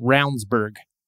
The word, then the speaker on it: Ravnsborg: Jason Ravnsborg is how it's spelled, but it's uh, apparently pronounced Roundsburg.